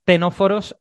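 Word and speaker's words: ctenóforos: Voy a explicar simplemente un poco lo que son los tenóforos y porque si alguien lo quiere buscar, aunque lo pronunciamos tenóforos, llevan una C delante, ¿vale? Son tenóforos.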